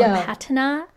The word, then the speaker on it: patina: She was talking about objects that have, I don't even remember how to pronounce it, patina or patina. – Mm-hmm. Patina.